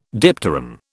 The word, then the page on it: dipteran: British English